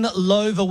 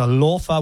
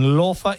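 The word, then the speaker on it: Loewe: When Lova were created, they never thought for a second that Aussies would be pronouncing their beautiful name Lova. – Or Lo. – You're right. – The way that it's spelt.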